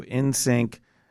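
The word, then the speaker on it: *NSYNC: Do you pronounce the asterisk? – It's silent. – It's silent. Mm-hmm. Okay. Mm-hmm. So NSYNC.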